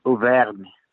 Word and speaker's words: Auvergne: And the area it's in, um, Auvergne.